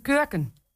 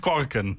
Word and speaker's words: kurken: Met zijn ze had met zijn kurken. – Oh. – Niet korken. – Nee, niet korken. – Kurken, zo spreek je dat uit.